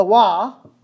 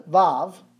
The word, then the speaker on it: Vav: The Wa, or the Vav, depending on this, how you pronounce it, and the dalat and another dalat.